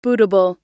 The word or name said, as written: bootable